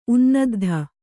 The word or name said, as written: ಉನ್ನದ್ಧ